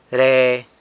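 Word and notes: re: San Lucas Quiaviní Zapotec

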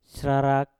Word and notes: srarak: mpur

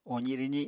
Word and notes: ozi-rwzi: Apatani

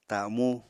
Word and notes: tamú: apatani

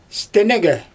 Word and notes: stɛnɛgɛ: Xyzyl

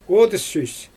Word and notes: odys süs: Xyzyl

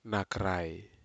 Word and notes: nakrai: Aneityum

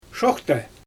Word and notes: ʃoxtej: Xyzyl

